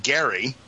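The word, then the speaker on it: Gerry: This goes back to I'm just happen to look up Elbridge, which his name was actually pronounced Gary.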